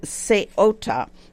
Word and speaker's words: Ceuta: The correct pronunciation of that little piece of land is Ceuta.